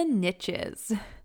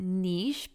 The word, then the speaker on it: niche: Today, we're getting specific on who we serve and what we do because the riches are in the niches. Now, I actually prefer to pronounce it niche because it sounds so fancy, but for the sake of my rhyme, I had to go with niche.